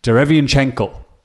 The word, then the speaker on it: Derevyanchenko: This is the hardest name in the world to pronounce. – Give it a go. – Derevianchenko. – Okay, yeah, we'll take that.